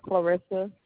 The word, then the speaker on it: Clarissa: Clarissa, C-L-A? – Uh-huh, C-L-A-R-I-S-S-A. – And what's your last name, ma'am? – Thompson, T-H-O.